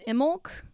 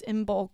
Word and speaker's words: Imbolc: So then the next ritual after that is Imolc. Some people pronounce the B, some people don't. It's Imbolc or Imolc, which is also in United States culture, that's Groundhog Day.